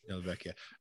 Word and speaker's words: Della Vecchia: Della Vecchia.